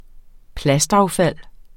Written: plastaffald

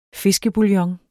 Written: fiskebouillon

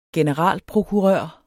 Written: generalprokurør

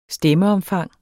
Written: stemmeomfang

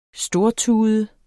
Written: stortude

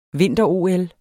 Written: vinter-OL

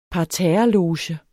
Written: parterres

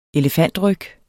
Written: elefantryg

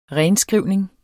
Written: renskrivning

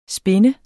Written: spind

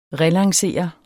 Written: relancere

